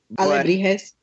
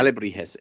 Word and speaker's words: alebrijes: Alebrijes. I'll say it for you. – Yeah, yeah. Alebrijes.